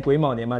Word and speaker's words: 癸卯: 这个二零二三年癸卯年嘛，